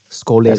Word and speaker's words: Skole: Yeah, actually it's pronounced as Skole Records. – Skole, yep.